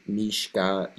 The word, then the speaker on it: Mishka: And my name is Mishka Yarovoy, MISHKA underscore YARovoy.